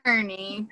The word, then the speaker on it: Kearney: And um, so you heard it here, Casey Kearney, not Kearney. Stop saying that. – See, is that's actually the proper way to say it. from oh. Ireland. – Apparently, it's Irish. Kearney is the proper way to say it, but there, my husband's family's from North Carolina, and it was changed to Kearney. So, just what it's been forever is Kearney. I love it.